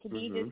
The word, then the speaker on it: Khadijah: And Khadija is spelled KHADIJAH, and then last name Brown, like the color, .com.